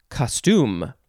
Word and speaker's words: costume: It looks like this one, you would emphasize the second syllable, so it would be costume. costume. I guess you could also emphasize the first syllable if you want as well.